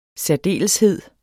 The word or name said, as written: særdeleshed